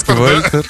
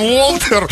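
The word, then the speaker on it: Walter: А я-то думаю, там, ну, по-русски Вальтер, да? А там Уолтер. – Ну вот. – Ну, Уолтер, это Уолтер. – Написано В, а потом идет А. – Это W, там как О читается.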